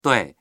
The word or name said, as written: dui4